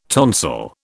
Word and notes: tonsil: British English

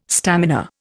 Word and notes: stamina: Indian

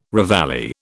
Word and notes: reveille: British English

